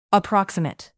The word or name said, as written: approximate